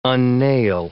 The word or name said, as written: unnail